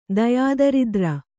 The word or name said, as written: ದಯಾದರಿದ್ರ